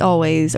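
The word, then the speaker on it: Alvvays: Before that, we were listening to Little Miss by Boa, and then Not My Baby by Always, which, by the way, I cracked the code. They do pronounce it Always, or at least they did in their Tiny Desk concert.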